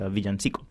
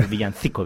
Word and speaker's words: villancico: It's a villancico, or villancico, depending on which, whether you use the Spanish or Latin American pronunciation.